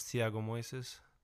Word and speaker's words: Thiago Moises: Very interesting bout between Islam Makhachev versus Thiago Moises.